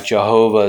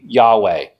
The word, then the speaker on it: Yahweh: The reason why they say that is this, quote, there was no J sound in ancient Hebrew. So obviously that would be hard to pronounce a J if you didn't use it. So most scholars think that the pronunciation of the name of God should be Yahweh.